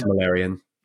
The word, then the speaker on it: Silmarillion: The Silmarillion, how do you pronounce it? Silmarillion.